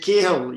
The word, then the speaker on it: Cahill: Is it Cahill? Yeah. – Yeah. – That's how you pronounce it.